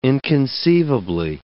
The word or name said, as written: inconceivably